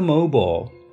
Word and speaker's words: mobile: 重点是我们要记住，I L E 在很多情况下面都可以直接偷懒，不用读成 I'll，而直接简单的读成 all。我们举了三个例子：China Mobile、 China Mobile、 T-Mobile、 Mobile Phone，还有土地肥沃（fertile）、 fertile，而不用读成 fertile。